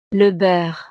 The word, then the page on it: le beurre: frances